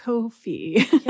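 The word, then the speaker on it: Ko-fi: So please, if you want to check that out, it's available on, because I don't want to butcher this, but it is pronounced coffee, right? – Oh my God, I have no idea. Oh, yeah. It's like Kofi.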